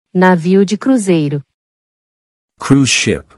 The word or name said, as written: Navio de cruzeiro